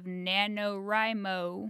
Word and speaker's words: NaNoWriMo: It is the beginning of NaNoWriMo. I think it's pronounced Rhymo.